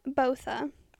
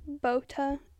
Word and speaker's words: Botha: So Alison Botha. Or Botha. It's pronounced both ways.